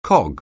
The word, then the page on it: cog: anglais